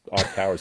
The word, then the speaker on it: iCAUR: iCar, which is spelled ICAUR, but that's iCar is how they were pronouncing it.